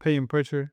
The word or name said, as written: Payton Pritchard